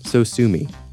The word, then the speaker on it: Sosumi: So sue me, except spelled S-O-S-U-M-I, as though it were a Japanese word.